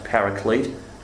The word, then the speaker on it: Paraclete: P-A-R-A-C-L-E-T-E. Sometimes pronounced Paraclete, I'd say Paraclete. So it's P-A-R-A-C-L-E-T-E.